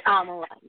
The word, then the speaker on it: Amla: Amla, do I have your name right? – It's Amla. – How do you spell that, sweetie? – I don't know if that's going to help you, because it's then I get called Amla. It's A-M-L-A. That's how you spell it.